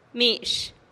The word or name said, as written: mi-x